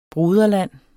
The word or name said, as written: broderland